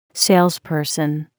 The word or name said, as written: salesperson